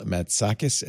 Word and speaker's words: Matsakis: You could find her on the Twitter at L Matsakis, M-A-T-S-A-K-I-S. Have I been saying that right the whole time, I hope? – Yeah, Matsakis. I think you got it.